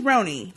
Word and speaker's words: Roni: My name is Roni, pronounced like macaroni, laugh out loud.